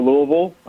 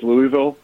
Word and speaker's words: Louisville: I think I've got the I had Creighton over Louisville.